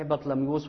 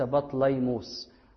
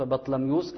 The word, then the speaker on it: بطلميوس: فلما نظر في النص اليوناني قرأ اسم بطلميوس الخامس، قرأ اسم بطلميوس الخامس، وهو النطق الصحيح بطلميوس وليس بطليموس، النطق بطلميوس الخامس.